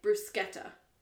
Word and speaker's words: bruschetta: I said, we'll start with the bruschetta, pronouncing it bruschetta, as it is pronounced in Italian.